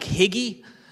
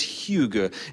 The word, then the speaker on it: hygge: It kind of looks like higgy, but it's not higgy. It rhymes with beluga. It's hygge.